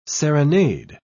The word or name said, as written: serenade